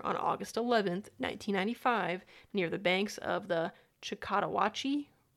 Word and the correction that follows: Choctawhatchee: River. – Choctawhatchee? – What? Is that how you say it? – Choctawhatchee?